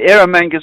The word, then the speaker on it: Erromanga: Yes, and I believe it's called the Erromanga Sea, did I say that right? – The Erromanga Sea is correct,